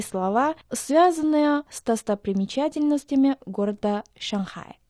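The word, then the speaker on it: Шанхай (上海): По-китайски его название звучит как Шанхай. Обратите внимание на то, что первый иероглиф «шан» произносится в четвертом тоне, а иероглиф «хай» – в третьем.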